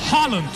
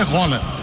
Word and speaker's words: Haaland: Eder Luiz, qual que é a pronúncia? O Galvão Bueno perguntou pra você. – Vamos falar o nome dele, né? – É. Como é que é? – Roland. – Roland? – Roland.